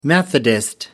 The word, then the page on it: methodist: British English